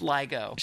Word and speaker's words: LIGO: Okay, because I thought you were just mispronouncing the word LIGO. – Nope. – Okay. – Nope, it's LIGO.